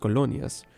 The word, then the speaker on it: colonias: It's pronounced colonias.